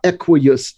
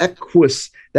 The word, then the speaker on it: equus: It's not equius, it's equus. That, that the first U goes with the Q and the second U makes the us sound.